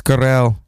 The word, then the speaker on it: Garrel: Michael Pitt, Eva Green, Louis Garrett. How do you say his name? Oh, it's Louis Garrel, who was in Little Women.